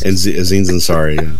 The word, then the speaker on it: Aziz Ansari: And that's one of the good things, like I said yesterday on the podcast, like that was the one good thing about the ANSI Ansari. I'm butchered that. Oh, man, why am I so bad with names? – Aziz Ansari, and they're screwing him over so hard.